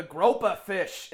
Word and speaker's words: groper: Hey, in Australia, it's called the groper fish instead of the grouper fish.